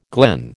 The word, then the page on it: glen: American English